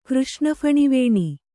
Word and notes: ಕೃಷ್ಣಫಣಿವೇಣಿ: Kannada

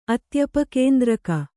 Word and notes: ಅತ್ಯಪಕೇಂದ್ರಕ: atyapakēndraka

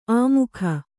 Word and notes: ಆಮೋಘ: āmōgha

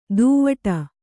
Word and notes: ದೂವ್ವಾಡ: dūvvāḍa

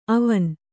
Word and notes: ಅವನ್: avan

